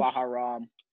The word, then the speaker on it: Bahrain: Bahrain, and Israel on the anniversary of 9/11 attacks on America.